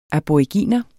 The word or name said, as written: ABORIGINER